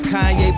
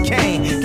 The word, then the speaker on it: Kanye: It's Kanye, but some of my plastics still say Kane.